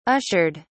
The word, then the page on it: ushered: British English